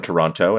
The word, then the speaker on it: Toronto: Are you allowed to pronounce the T in Toronto, by the way? – I have moved away from Toronto, and now I pronounce it correctly.